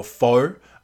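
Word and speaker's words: pho: We went out for Foe. And if you ever correct me and say it's actually pronounced Th, you can go on fuck yourself.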